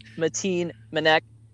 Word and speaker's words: Mateen Manek: Today we got a special guest, Mateen Manek. Did I say that right? I said that right? – Yeah, that's correct.